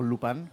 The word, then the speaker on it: Lupin: en donde era entre amiga y enemiga de Lupin, o Lupin, como se, pronuncia correctamente